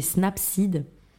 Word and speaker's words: Snapseed: Moi, une application que j'aime beaucoup, c'est Snapseed. Alors, ça s'écrit Snap et ensuite Seed, c'est S-E-E-D.